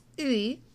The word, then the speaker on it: 의: Now on its own, e should be pronounced as ui, ui, ui, since it's just ung, u, and e combined. But luckily for us, Koreans can be kind of lazy and they tend to draw this particle out, so we get to pronounce it as e.